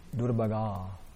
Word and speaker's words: Durabhaga: Durabhaga. It's a long A.